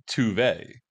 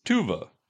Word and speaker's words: Tove: Well, and the funny part is, it's not even really Tuve. It's Tuva.